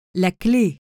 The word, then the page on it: la cle: acute accent